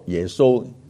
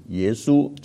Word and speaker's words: 耶稣: 新约耶稣是希腊文希苏翻译过来的，所以你用普通话来读耶稣，耶稣就很近希伯这个希腊文的发音，但是翻了广东话之后就变成耶稣就不近了，所以呢是一种误会，以为呢是爸爸叫耶和华，大家都姓耶，个仔叫耶稣。